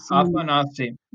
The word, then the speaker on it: Afanasy: And then there's Afanasy Mamedov. Did I pronounce that correctly? – Afanasy.